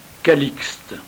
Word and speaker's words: Calixte: Le 14 octobre, nous fêtons Saint Calliste ou Calixte. Calliste est la prononciation ancienne et Calixte la prononciation plus récente.